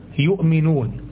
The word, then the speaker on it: يؤمنون: فكلمة يؤمنون، يؤمنون، هو هل هنا في واو أصلا في النطق؟ هل في واو؟ هل أنت شايف واو في النطق؟ يؤمنون، لا، مفيش واو.